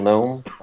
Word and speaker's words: gnome: The little gnome. I don't know. – So I haven't seen it. Do they pronounce the G in everything? – No.